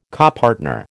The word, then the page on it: copartner: American English